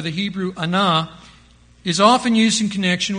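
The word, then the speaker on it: anah (ענה): The Hebrew word for afflict that we have been reading and afflict our souls, the Hebrew word is spelled A-N-A-H, anah. A-N-A-H, anah.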